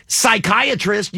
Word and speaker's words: psychiatrist: Psychiatrist, you don't pronounce the P.